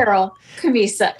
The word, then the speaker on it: Carol Camisa: Is it, how do you pronounce it? – It's Carol Camisa. It's just a different spelling.